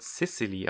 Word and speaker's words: Sicily: Bei Sizilien ist es allerdings ein besonderer Fall, also ihr Name wird Sicily ausgesprochen, aber in allen Subs ähm, wird sie Sizilien genannt, wie die Insel.